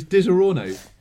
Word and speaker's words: Disaronno: It's how it's spelt. It's Disaronno.